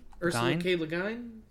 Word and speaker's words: Ursula K. Le Guin: Ursula K. Le Guin? – I feel really bad for her.